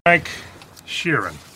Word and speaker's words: Frank Sheeran: Frank Sheeran, I say that right? – Yeah, you said right.